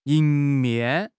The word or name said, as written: 迎面